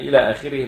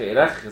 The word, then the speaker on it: إلخ: وإحنا قلنا إن إلى آخره المنحوتة دي اللي هي مختصرة دي اللي هي إلخ، بترسم هكذا وتنطق إلى آخره. لو حد نطقها وإلخ إلخ زي ثلاثي أضواء المسرح كده يبقى إيه؟ يبقى غلطان، واطلع من نقرة لدحضيرة ومن فخ الفخ.